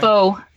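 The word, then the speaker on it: phở: How do you pronounce it? Come on, tell me. – Pho. – Yeah, pho. Okay. Pho.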